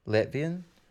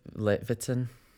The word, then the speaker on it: Levitin: Letvian? How do I say that? – Levitin.